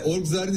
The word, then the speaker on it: Orgzar: Orgzar'ın ismini doğru söylüyor muyum, doğru telaffuz ediyor muyum? – Evet evet, doğru telaffuz ediyorsunuz.